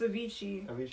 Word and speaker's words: Avicii: It's Avicii.